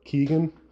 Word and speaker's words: Keoghan: It's like key. It's like Keoghan. So Keegan. Keoghan.